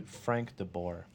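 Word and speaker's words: Frank de Boer: And they had the CCL matches, and I can't remember, DeBauer? How do you say their new coach's name? – Frank DeBoer.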